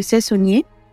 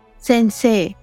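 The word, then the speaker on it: सेंसे (先生): से सुनिए सेंसे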